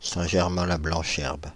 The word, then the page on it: Paris--Saint-Germain-la-Blanche-Herbe: French